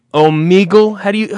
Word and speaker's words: Omegle: I think it's O-M-E-G-L-E. Omegle? Omegle? How would you say you pronounce that? – Omegle.